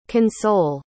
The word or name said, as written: console